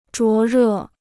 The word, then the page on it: 灼热: zhuó rè